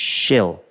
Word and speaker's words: Shil: So I've not been able to say my name without it sounding like this. Shil. And that's what I've been used to my whole life. Well, for, for most of my life. So my main driver was I want to be able to say my name without that elongated shh.